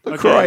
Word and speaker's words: LaCroix: LaCroix is right. No, it's not the right thing that you think is like the fancy French pronunciation.